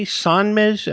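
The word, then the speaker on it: Sonmez: We have John, do I say Sanmez? Am I saying that right? – You're saying it perfect.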